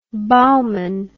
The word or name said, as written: bauman